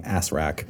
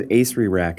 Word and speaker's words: Acererak: Or as some players are fond of saying, Asrak. – Asrak. – Yes. – For me, it's always Acererak, because that's how I remember how to spell it.